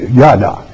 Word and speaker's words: yahdaw: And it comes from yada, Y-A-H-D-A-W, yada.